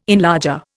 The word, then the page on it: enlarger: Indian